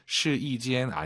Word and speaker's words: 试衣间: 试衣间, 试衣间 아니에요. 숫자 1이 아니라 여기서는 YI 발음.